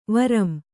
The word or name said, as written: ವರಂ